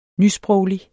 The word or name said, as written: nysproglig